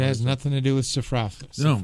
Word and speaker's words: sophrosyne: S-O-P-H-R-O-S-Y-N-E. Sophrosyne, or sophrosyne, if you prefer. – Sophrosyne? – It's another word, another way you can pronounce it. Sophrosyne or sophrosyne. – S-O-P-H-R-O-S-Y-N-E-S.